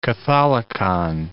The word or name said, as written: catholicon